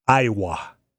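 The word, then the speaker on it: Aiwa: Is it pronounced like the state? – That's what I'm wondering. It's A-I-W-A. Iowa.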